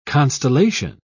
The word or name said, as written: constellation